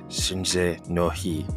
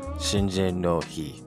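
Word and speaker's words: Seijin no Hi: So your Japanese word of the day is Shinjin no Hi, which means coming of age day. How do you spell that? That's SEIJINOHI, Shinjin no Hi.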